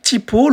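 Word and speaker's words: T'Pau: Et donc Typo, le nom Typo, comment il s'écrit Alors ça s'écrit pas comme je le prononce. En fait, c'est T apostrophe PAU, ou comme euh, vous le voyez euh, en lancement du podcast, en le titre de l'épisode, vous voyez comment il est écrit.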